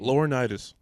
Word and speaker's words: Laurinaitis: Laurinaitis.